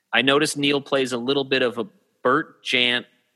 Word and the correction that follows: Bert Jansch: I'm going to say it wrong. Bert Jansch. – Oh, Bert. Yeah, it's Bert Jansch.